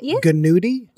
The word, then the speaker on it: gnudi: I'm not pronouncing the G on any of these. You see? – Nudie and... So is there a G on nudie? – Yeah. – Gnudi? – Yeah. – So it's like Gnudi and Gnocchi.